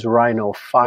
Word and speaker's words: rhinophyma: What about I will, as I usually do here, Josh, object to your pronunciation. It is rhinophyma.